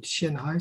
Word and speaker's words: Qianhai: Euh, euh, ils, ont, ils ont fait un quartier de Shenzhen qui s'appelle Euh, Tianhai ça s'écrit Q-I-A-N H-A-I, dans le même mot.